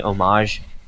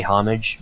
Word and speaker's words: homage: I didn't, at the time, know how to say homage properly, so I used to say homage because I've never read the word anywhere else before. – To be fair, a lot of people did, and it's mostly because silent H's are the bane of the English language.